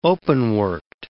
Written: open-worked

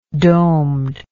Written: domed

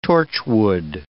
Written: torchwood

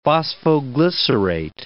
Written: phosphoglycerate

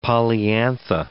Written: polyantha

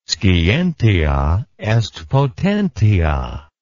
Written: scientia est potentia

